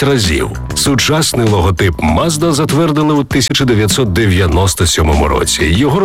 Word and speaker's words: Mazda: Назва також походить від імені засновника компанії Мацуда, яке японською вимовляється як Mazda.